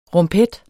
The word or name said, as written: rumpet